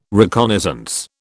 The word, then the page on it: reconnaissance: British English